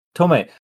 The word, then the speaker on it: Tomei: And I really love how immediately after he says that, I think Marissa Tomei, is that how you say it? – Tomei.